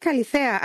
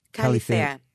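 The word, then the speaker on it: Kallithea: It's called Kalithea, actually. – Is that, how would you spell that? – It's K-A-L-L-I-T-H-E-A, Kalithea.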